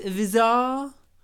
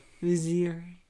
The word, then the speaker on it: vizier: It isn't pronounced Vizar. That's the role that Jafar had. Who's a Vizar? – Vizier.